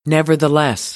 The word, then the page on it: nevertheless: American English